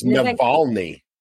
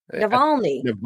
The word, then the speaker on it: Navalny: It's Navalny. – Navalny.